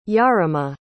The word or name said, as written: yarema